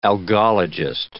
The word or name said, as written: algologist